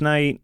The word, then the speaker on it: Knight: And then if you want to find me, you can either Google my name, Stephen Nicholas Knight, STEPHEN, Knight spelled with a K, or that's my Instagram handle too.